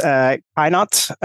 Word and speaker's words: Keinath: My last name is Keinath.